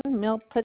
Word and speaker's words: Milpitas: Milpitas.